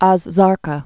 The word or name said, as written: Az Zarqa'